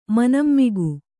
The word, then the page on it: ಮನಂಮಿಗು: Kannada